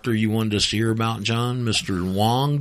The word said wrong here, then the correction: Ong: did you say his name is? – Dr. Ong. – It's Dr. Ong, O-N-G, Dr. Ong.